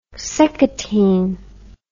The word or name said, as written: seccotine